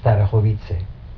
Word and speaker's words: Starachowice: And then we moved, when I was 7 years old, we moved to another city, Bieżnik Starachowice. – Could you spell it for me? – Starachowice is S-T-A-R-O-W-I-C-E-A, Starachowice.